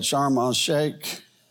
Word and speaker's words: Sharm el Sheikh: Sharm el Sheikh.